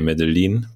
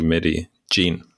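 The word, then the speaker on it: Medellín: So in German, we would say Medellin, but it's actually Medi Jean.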